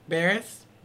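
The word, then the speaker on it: Buress: How do you pronounce his last name? – Burris. – Burris? – That's how I always said it.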